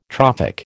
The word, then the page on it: trophic: American English